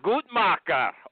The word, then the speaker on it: Gutmacher: Anything but nutcracker is just fine. – Okay. How do you say it? – I go with the more German Gutmacher. – Yeah, Gutmacher.